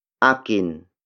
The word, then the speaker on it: akin: Word for word, it's nasa, akin, ang, pitaka, mo. Nasa, akin, ang, pitaka, mo.